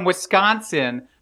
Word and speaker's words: Wisconsin: See, because it's from Wisconsin, but they pronounce it Wisconsin.